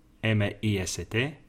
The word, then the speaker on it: chemist: Cuidado porque se deletrea Chemist, pero no se dice chemist, se dice chemist.